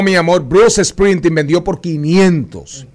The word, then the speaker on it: Springsteen: ¿Cómo se pronuncia eso? Springsteen. Bruce Springsteen.